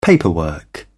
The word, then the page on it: paperwork: British English